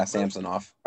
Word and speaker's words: Samsonov: They're going to roll out with Elias Samantsov. – Okay. Again, I butchered his name. – Okay, yeah, I know who you're talking about. Samsonov? – Is that yeah, Samsonov.